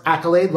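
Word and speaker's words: Akylade: It's A-K-Y-L-A-D-E. And it's pronounced Accolade, like you got an accolade because you did great things.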